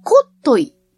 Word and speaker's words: 特牛: そこへ行くと美味しい牛肉が食べられるのかしらねっていう話になって調べてみると特牛と書いてコットイと読むそうです。